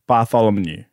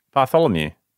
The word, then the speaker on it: Bartholomew: Is it Bartholomew or Moo? Now I've lost it. – Bartholomew. – It's Mew.